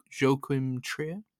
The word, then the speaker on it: Joachim Trier: And uh it is the kind of um, final film in the Oslo trilogy from don't know how to pronounce his name, so I'm probably butchering it here, but jo- Joachim Trier. – Yeah, yeah, that's right.